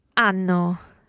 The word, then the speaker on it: hanno: Hanno. – And turning back to the they form, once again, there's that silent H.